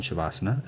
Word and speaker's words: Shavasana: And I would go in Shavasana. Am I saying that right? – Mm-hmm.